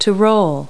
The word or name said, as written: roll